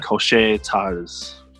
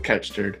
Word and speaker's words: Couche-Tard: It's supposed to be like a French pronunciation since there's like French parts of Canada. And it's like Cochet Tars. Tar. Or something like that.